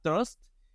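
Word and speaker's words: TR/ST: Este, otro de, esos proyectos que me gusta mucho y que hasta hace poco supe cómo se pronunciaba era Trust, pero que se escribe TR-ST y que es este eh,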